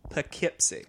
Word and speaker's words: Poughkeepsie: Sorry. I fucked it up again. – Poughkeepsie. – Yeah, I said Poughkeepsie. Poughkeepsie. That's how it's spelled. – Yeah, right? Like Doughkeepsies. – Yes. – Yeah. – But apparently it's Poughkeepsie.